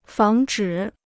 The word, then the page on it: 防止: fáng zhǐ